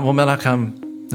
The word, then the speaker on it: Menachem: Well, Menachem, am I pronouncing that okay? – Yes.